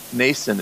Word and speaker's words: Nasson: and the last name is Nason. It's spelled N-A-S-S-O-N.